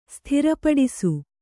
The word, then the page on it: ಸಾದರಪಡಿಸು: Kannada